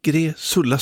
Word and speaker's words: Gräsulla: Eller Gräsulla, det var svårt att uttala det där. Gräsulla, så ska det vara.